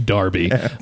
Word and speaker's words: Derby: First of all, it's pronounced Darby.